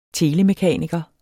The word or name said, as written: telemekaniker